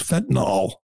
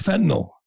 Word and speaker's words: fentanyl: By the way, he doesn't even say it right. He says fentanyl. It's fentanyl, Joe, and it's killing people, and you don't give a damn.